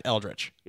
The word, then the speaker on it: Eldritch: So the clarity is that it is in fact Eldritch.